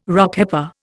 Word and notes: Rockhopper: Indian

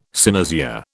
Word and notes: cynosure: British English

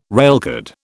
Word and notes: railcard: British English

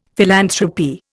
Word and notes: philanthropy: Indian

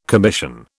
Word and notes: commission: British English

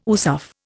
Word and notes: USAF: Indian

